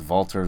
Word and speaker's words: Walter: It's spelled Walter, but he pronounces Walter as like a V in there.